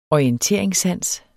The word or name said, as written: orienteringssans